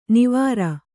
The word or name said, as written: ನವಿರು